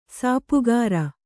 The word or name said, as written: ಸಾಪುಗಾರ